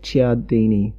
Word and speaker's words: Cialdini: and I'm not going to start now because I can't pronounce his surname. – Yeah, Robert Cialdini. – I hear Cialdini. – Cialdini, or which sounds spelled like Cialdini, but yep. I think he said Cialdini.